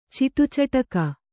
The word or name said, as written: ಚಿಟುಚಟಕ